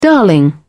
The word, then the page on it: darling: British English